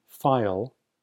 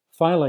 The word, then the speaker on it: Pfeil: So what did file, that's spelled P-F-E-I-L, what did Fred File identify as underlying the problems he foresaw?